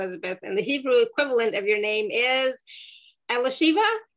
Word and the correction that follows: Elisheva: It's it's Elisheva. Elisheva.